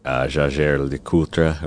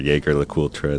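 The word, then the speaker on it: Jaeger-LeCoultre: So, and during that time, I also reached out to a few brands, Jaeger LeCoultre, or Jaeger LeCoultre, as we say in the U.S.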